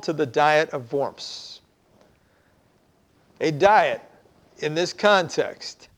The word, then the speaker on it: Worms: Worms, which when we look at it in the English, looks like worms, but in the German, W is pronounced with a V. So it's Worms.